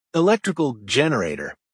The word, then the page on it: electrical generator: American English